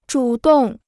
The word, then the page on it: 主动: zhǔ dòng